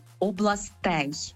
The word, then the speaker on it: областей: В усіх відмінках множини та однини у слові «область» наголос на першу літеру «о», окрім родового відмінку множини. Тому справа тут в цьому родовому відмінку множини, а не в інших. Тільки там виступає наголошене закінчення «ей», «областей».